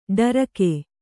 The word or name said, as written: ಡರಿಕೆ